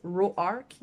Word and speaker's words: Roark: Sinon, il y a aussi le personnage de Hélène, dans le sud, son nom se prononce Roark, vraiment comme Row arc. – séparé.